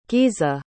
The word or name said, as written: giza